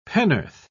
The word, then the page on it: pennorth: British English